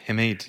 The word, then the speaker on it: Himid: Himid, yes.